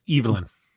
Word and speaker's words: Evelyn: Yes, Evelyn. – Yeah, that's the British pronunciation of Evelyn. – Yes, thank you. – Or at least her pronunciation, Evelyn, yes.